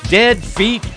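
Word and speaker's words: Ded Fete: Feet, Dead Feet Sound. And that is spelled D-E-D. That's one word. F-E-T-E. That's the second word.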